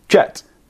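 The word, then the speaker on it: Djet: The René Bonnet de Jet. – Yeah, the Jet, yeah. Apparently he put the D in front because he knew that French speakers and other romantic language speakers would call it a yet.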